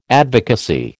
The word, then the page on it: advocacy: American English